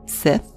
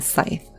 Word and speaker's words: scythe: I believe the correct pronunciation is scythe, because as I'm saying it out loud, I believe Sith is something from Star Wars.